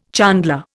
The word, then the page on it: chandler: Indian